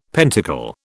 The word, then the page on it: pentacle: British English